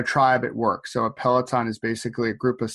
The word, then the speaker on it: Peloton: So Peloton Coaching, a lot of people like sort of link it to those exercise bikes, Peloton cycles.